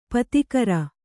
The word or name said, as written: ಪದಗಾರ